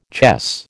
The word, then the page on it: chess: American English